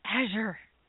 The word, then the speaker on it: Azure: Azure. Is how you say that.